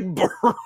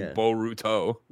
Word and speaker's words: Boruto: Well, I'm saying, oh, because he's Naruto, and this would be Boruto.